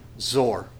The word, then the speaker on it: Tyre: And are probably better pronounced as Zor, but we'll just call it Tyre because it actually looks like Tyre.